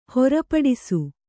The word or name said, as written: ಹೊರಪಡಿಸು